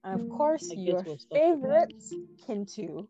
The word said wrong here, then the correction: Kintu: You know, it's actually pronounced Chintu. Chintu, yes. That K is actually a ch in Ugandan.